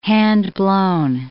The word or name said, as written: handblown